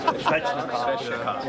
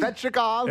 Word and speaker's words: Svechnikov: You know, Nekas, Shvechnikov. Is that how you say it? – Shvechnikov.